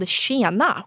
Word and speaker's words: Tjena: So, as an example, there was like this Tupperware line called Tjena, which is how you say like hey in Swedish.